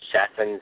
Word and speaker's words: Chaffin: So the editor, I think, C.E. Chaffin is how you pronounce it, C-H-A-F-F-I-N.